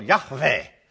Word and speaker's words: Yahweh: And, you know, you use your lips, what, to pronounce the W. But on the V, you go labial dental. That's too technical, Teresa, but that's. So in the modern Hebrew, it would probably better pronounce Yahweh. And even in, you know, if you want to get real technical, go over to Dr. Brueggemann, the Hebrew Old Testament, and he would do a little Yahweh.